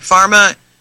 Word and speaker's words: pharma: YouTube, type in YouTube-Big Bucks, Big Pharma. Boy, that's information that's really powerful. Let you know what's really going on. – Well, remember, in pharma, that P is silent. It's harm.